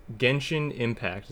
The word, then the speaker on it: Genshin Impact: Genshin Impact. Is that how you pronounce that? – I believe so, yeah.